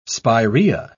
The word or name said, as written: spirea